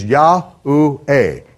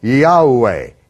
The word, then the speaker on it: Yahweh: So phonetically, the Yod-Heh-Vav-Heh would be phonetically pronounced as Yah-U-Eh, Yahweh, Yahweh. With Yod is the Yah, the He is Ah, the Vav as a vowel is U, and the last He is A. So it's Yahweh, Yahweh.